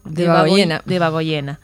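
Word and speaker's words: Debagoiena: Por otro lado, en referencia a este mismo tema, familiares de víctimas del franquismo de Debagoyenea, a ver, lo pronuncié mal, ¿no? – De Bagoyena.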